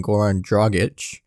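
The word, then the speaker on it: Goran Dragić: Bam Adebayo and Goran Dragic finally said his name correct. If you're here from previous episodes, I kept on mispronouncing his name, so finally got it right this time.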